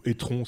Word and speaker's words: e-tron: on parlait des, caméras à la place des, des rétroviseurs sur la fameuse Audi e-tron. – ou étron, ça dépend comment on le prononce.